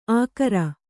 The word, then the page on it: ಆಕರ: Kannada